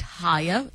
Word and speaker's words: Haier: Brand and Media Manager at Hire. That is spelled H-A-I-E-R.